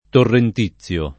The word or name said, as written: torrentizio